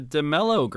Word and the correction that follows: D'Amelio: I think it's DeMello. – Oh, Charlie D'Amelio or whatever? – D'Amelio, yeah. Something.